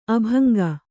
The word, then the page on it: ಅಭಂಗ: abhaŋga